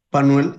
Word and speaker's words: Manuel: Manuel Angulo. – Can you please spell it? – M-A-N-U-E-L, Angulo, A-N-G-U-L-O.